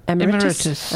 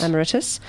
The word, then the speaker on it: Emeritus: How do you pronounce that, Miss? Emeritus. – Emeritus. Sounds like the airlines, really.